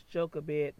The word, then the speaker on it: Jochebed: But her name is Jochebed. Now, it is spelled J-O-C-H-E-B-E-D.